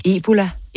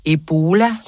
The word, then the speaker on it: Ebola: Ebola. Ebola.